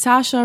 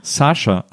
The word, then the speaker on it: Saoirse: Saoirse Ronan. – Saoirse.